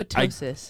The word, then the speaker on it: ptosis: Yeah, ptosis. – Well, my ptosis, I feel like one of those letters is silent. Big. – Oh yeah, well that is probably silent, huh? – Yeah.